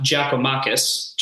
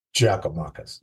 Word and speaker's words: Giakoumakis: And Gigi, his name is pronounced Giakoumakis. Giakoumakis. There you go.